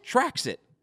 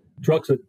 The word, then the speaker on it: Trauxit: Traxit? – Truxit.